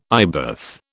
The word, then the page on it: eyebath: British English